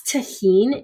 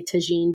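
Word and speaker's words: tajín: So maybe if you've never had that, I think it's tajin is the way it's pronounced, but I know a lot of people say tajin, but I add that into my pico de gallo as well.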